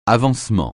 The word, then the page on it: avancement: francais